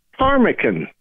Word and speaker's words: ptarmigan: They're spelled with a P-T-A-R-M-I-G-A-N. The P is silent.